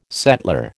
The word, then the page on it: settler: American English